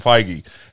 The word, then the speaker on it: Feige: Is it Feige? – Feige.